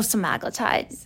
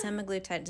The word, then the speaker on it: semaglutide: Oh, semaglutide. – Semaglutide.